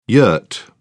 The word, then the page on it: yurt: British English